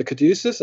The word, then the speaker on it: caduceus: The caduceus, I think that's how you say it.